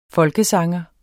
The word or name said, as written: folkesanger